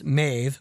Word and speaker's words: Medb: Pronounced Maeve, which is spelled M-E-D-B, but pronounced Maeve because Irish is weird, is what he says.